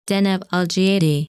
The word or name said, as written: Deneb Algiedi